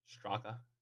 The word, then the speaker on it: Straka: Straka. You were close. Straka.